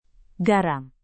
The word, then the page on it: garam: indonesian